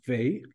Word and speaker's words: West: A large apartment in Berlin West, the magic West pronounced Weh, like Oy Weh, in German,